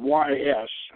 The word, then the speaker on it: Ys: Now, two adjacent seacoast promontories, the prehistoric monoliths of Karnak and the sunken city or castle of Ys, which is spelled Y-S and pronounced Ys.